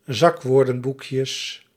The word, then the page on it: zakwoordenboekjes: Dutch